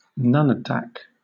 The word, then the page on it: nunatak: British English